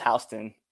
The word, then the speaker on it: Houston: Is that how you say it? Houston? – Yeah, it's Houston.